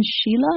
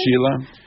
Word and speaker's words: Schiele: Egon Schiele, I guess. – Schiele.